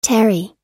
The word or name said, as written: tarry